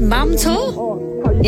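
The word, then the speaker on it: Mam Tor: What's it called? Mam Tour? Yeah, I said that right.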